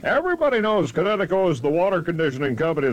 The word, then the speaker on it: Kinetico: Son, you should have remembered kinetic as in kinetic energy with an O on the end. Kinetico. – Kinetico. Spell it K-I-N-E-T-I-C-O.